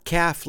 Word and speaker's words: calf: Not calf like baby cow calf. By the way, how do you spell calf, which is a baby cow? C-A-L-F. Remember, the L is silent. No, I like to order a half calf, which is C-A-F-F, short for caffeinated, because it has 50% caffeine and 50% decaf, and it's a nice blend of regular and decaffeinated beans.